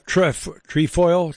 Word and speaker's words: trefoil: It's the bird tail trefoil.